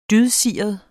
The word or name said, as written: dydsiret